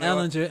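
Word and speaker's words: Ehlinger: Sam Ellinger, emphasis on the L.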